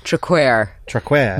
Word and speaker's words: Traquair: Traquair. Make sure it's not. All the English seem to put queer on.